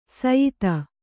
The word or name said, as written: ಸ್ಯೂತಿ